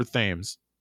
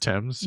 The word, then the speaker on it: Thames: It's River Thames, Thames, right? Thames. Yeah, I knew. I knew I fucking said that wrong.